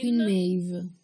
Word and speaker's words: Queen Maeve: Queen Maeve. – Queen mm. Maeve, sì, no, senso. Queen Maeve, praticamente, mh, mm, diciamo che c'è questo rapporto tra Queen Maeve e Starlight, perché sono le uniche donne dei The Seven.